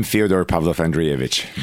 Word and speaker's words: Fyodor Pavlov-Andreevich: I'm Fyodor Pavlov-Andreevich, but that's...